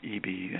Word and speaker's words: Eby: And you can also Google just David Eby and cello.